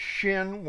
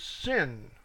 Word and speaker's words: Shin: Now there's two pronunciations of that. One is Shin, one is Sin, without the H.